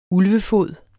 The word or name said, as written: ulvefod